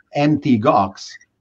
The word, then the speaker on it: Mt. Gox: As an illustration of your point, that the gamers flocked to Bitcoin, MT Gox, which is not Mt. Gox as some mistakenly pronounce it, was one of the first Bitcoin exchanges, then famously went bankrupt.